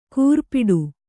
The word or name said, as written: ಕೂರ್ಪಿಡು